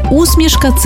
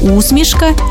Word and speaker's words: усмішка: Виявляється, що усмішка – це про невербальне транслювання чогось доброго, смішного. До речі, тут подвійний наголос. Усмішка і усмішка, як у Василя Симоненка.